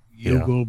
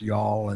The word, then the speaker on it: Youghal: And a lot of them be on ships leaving Yall, Y-O-U-G-H-A-L. – Uh-huh. – Yugal, but it's pronounced Yall.